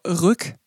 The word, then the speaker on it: RYK: Und zwar heißt der Künstler Rück R-Y-K, aber spricht man Rück wohl aus mhm.